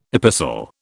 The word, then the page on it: epistle: British English